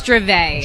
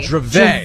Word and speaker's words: Dravet: And so Thomas has Dravet. Am I saying that right? Dravet syndrome. Grab the microphones right there in front of you guys. – There you go. – It's Dravet. – Dravet.